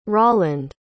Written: rolland

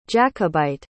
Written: jacobite

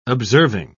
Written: observing

